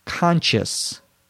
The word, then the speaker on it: conscious: Saw's question has to do with the difference between conscience, C-O-N-S-C-I-E-N-C-E, and conscious, C-O-N-S-C-I-O-U-S. Conscience versus conscious. You note a slight difference in the pronunciation. Conscience, conscious.